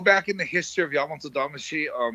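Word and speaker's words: Yamato Damashi (大和魂): Because it's a, am I saying that right? Am I messing that up? Yamato Damashi? – No, you're saying it good. That's good. That's exactly how it's said.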